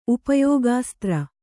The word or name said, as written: ಉಪಯೋಗಾಸ್ತ್ರ